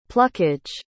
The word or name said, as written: pluckage